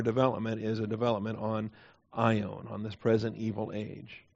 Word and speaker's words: aion: The noun for age is aion, A-I-O-N, alpha, iota, omega, nu. A-I is the long O, omega, aion.